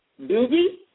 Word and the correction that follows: Dougie: The Dougie.